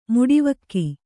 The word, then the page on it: ಮುಡಿವಕ್ಕಿ: muḍivakki